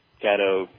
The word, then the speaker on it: Caddo: I mean, you know, Caddo, Cato, you say Cato, I say Caddo, whatever.